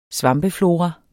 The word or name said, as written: svampeflora